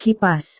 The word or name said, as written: kipas